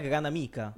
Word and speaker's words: Granamica: G- della, corretta pronuncia granamica o granamica? – Ma è granamica. Gran... Granamica, sì. Gra- granamica, esatto, granamica, no, perfetto.